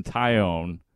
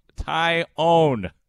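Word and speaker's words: Taillon: By the way, Tyone, not Tyon, Tyone. Man, I kind of liked it when Tyone was injured because then nobody mispronounced his name. It was great. Tyon. I know. It looks like Tyon. The man's name is Jameson Tyone.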